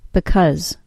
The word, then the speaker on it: because: To say the reduced form, the second syllable is reduced to a short U sound. Because.